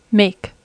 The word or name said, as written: make